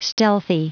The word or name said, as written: stealthy